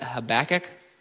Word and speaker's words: Habakkuk: And you'll notice I pronounce it Habakkuk. We'll see how the other guys pronounce it as the weeks go on. That's my pronunciation of choice.